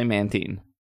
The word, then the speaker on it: Mantine: Look, I'm going off the 3D what? Pro, the Pokemon Battle Revolution, Serebii's pronunciation guide, and the anime. They all say Mantine.